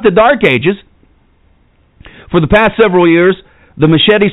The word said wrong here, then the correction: Mattachine: Society of Washington, I guess that's how you say it, the Mattachine Society of Washington, a revised version of a group originally founded in 1961, has been seeking to document evidence of the U.S.